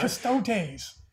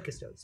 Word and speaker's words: Custodes: It's a Custodes Dreadnought, but Custodes. – Custodes. I've always said Custodes, so I'm going to say it. I'm not going to break my habit now. – You put the emphasis on the wrong syllable.